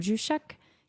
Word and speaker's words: Juszczyk: Juszczyk? I always say it wrong. Juszczyk? Yeah. – Yeah. Jay-Z.